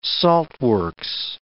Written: saltworks

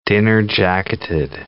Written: dinner-jacketed

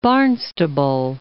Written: Barnstable

